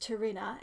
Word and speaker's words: Tarina: And he accepts that this is going to be the work of a long time and his rapport with Tarina, and yes, I ship it. Also, I'm just checking. Did I get her name right? – I think yes.